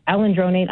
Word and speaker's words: alendronate: The doctor wants to put me on alendronate.